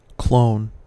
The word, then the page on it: clone: American English